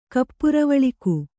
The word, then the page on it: ಕಪ್ಪುರವಳ್ಕು: Kannada